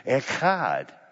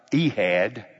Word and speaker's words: echad: It's a hard H, which means people need to stand a distance away from you when you say it. Echad, not Ehad. Don't pronounce it that way. It's not Ehad. It's echad.